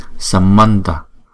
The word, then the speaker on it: samanta: Mấy chữ A này chúng ta đọc là ơ, chứ không đọc là A, đúng không? Cho nên là Samanta. Nên chúng ta đọc là Samanta, Samanta.